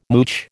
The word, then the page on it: mooch: American English